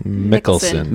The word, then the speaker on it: Mikkelsen: It's in Danish, and it stars Mads Mikkelsen. Am I saying that right? – Mikkelsen. – Mikkelsen. Mads Michelob.